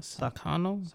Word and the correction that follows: Sakhno: Sak Sak Sakhno. – Sakhno.